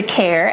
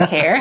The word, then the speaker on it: Kejr: So as he says, I'm Lisa, Lisa Kerr. Actually, although my last name is, you might see it as K-E-J-R and not pronounce it as Kerr.